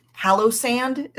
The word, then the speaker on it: Palossand: I don't know, maybe this is cheating because I picked Palossand is how you say it, which again, this is a pure aesthetic.